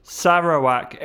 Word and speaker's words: Sarawak: Sorry, how do you say it, locals? Sarawak. Oh, you mean Sarawak.